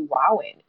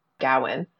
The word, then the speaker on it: Gawain: So I will probably end up defaulting to Gawain, but that putting the emphasis on the first syllable like that,